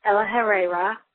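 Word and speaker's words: El-ahrairah: First off, I think it was Morgan that said she didn't understand a lot of names in the opening sequence with Ella Herrera. Yes, that's how you pronounce his name, which I only know because it was in the Tale from Watership Down book, which I don't recommend so much.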